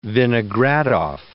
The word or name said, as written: Vinogradoff